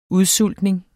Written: udsultning

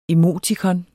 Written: emotikon

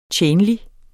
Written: tjenlig

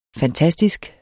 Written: fantastisk

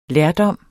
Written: lærdom

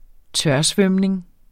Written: tørsvømning

